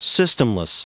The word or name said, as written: systemless